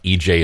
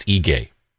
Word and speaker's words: Ige: The governor's name is not EJ, it's Ige.